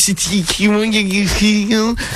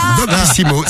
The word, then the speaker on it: Doctissimo: Doctissimo. – C'est Julien. – Attends, j'étais en train de réfléchir. – Julien, donc. – Ouais, Julien. Donc, de Paris, il a écrit à Doctissimo, là, rubrique addiction sexuelle.